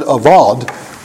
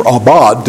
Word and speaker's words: avad: Well, the word that's used in the Hebrew, the word avad, or abad, depending on how you view it as a bet or a vet, anyway, how you pronounce that Hebrew letter.